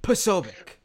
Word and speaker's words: Posobiec: And like, I got like a Polish pronunciation for it because apparently it's a Polish name, but it's Posobiec.